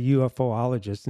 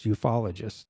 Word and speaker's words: ufologist: She's been letting me say, she's been letting me say UFOologist and it's ufologist.